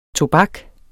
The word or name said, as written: tobak